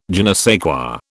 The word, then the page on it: je ne sais quoi: British English